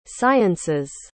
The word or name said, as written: science's